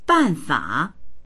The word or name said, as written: ban4fa3